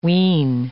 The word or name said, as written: wheen